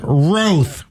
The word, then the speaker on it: Roth: Ryan Roth rhymes with growth, like the thing on his head.